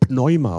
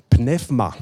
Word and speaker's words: Pneuma: Geist, Geist heißt Pneuma oder Pnefma, so, so spricht man das aus.